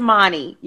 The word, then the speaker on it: Mani: it's Monty. Yeah, it's short for Imani.